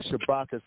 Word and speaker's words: Shabaka: And then you can follow me on Facebook, Shabaka Sankore. The first name is spelled S-H-A-B-A-K-A.